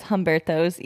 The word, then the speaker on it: Umberto's: Well, I've never heard anybody pronounce it that way. Maybe that's the traditional way to say it in Spanish, but everyone says Humberto's, even the Mexican people I know. So may or may not be pronounced that way.